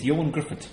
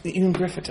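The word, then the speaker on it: Ioan Gruffudd: Nobody seems to pronounce his name. It's Ewan Griffith. – Ewan Griffith.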